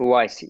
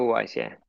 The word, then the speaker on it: Uwais: Uwais. Eco Uwais, Uwais.